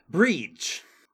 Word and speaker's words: Brigid: It, it, was originally a pagan festival uh, celebrated to uh, I want to get pronounce this right. Breege. Oh. Uh, spelled B R I G I D.